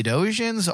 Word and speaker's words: Edosian: I believe is how you Edosian, pronounce that, yes, species, yeah, which I believe is now the second time we've seen a member of this species in Lower Decks.